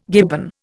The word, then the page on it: gibbon: Indian